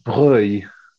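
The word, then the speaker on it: Breuil: I'm going to go with Bruy as the name of the place.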